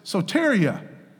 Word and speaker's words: soteria: Now that salvation is I want to say this, soteria. Soteria. That's the correct pronunciation.